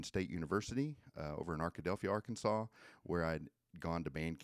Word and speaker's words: Arkansas: Isn't it Arkansas? – No, it is Arkansas.